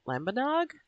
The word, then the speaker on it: Lambanog: They were also teaching him about, I'm probably going to say this wrong, Lambanog? – Yep.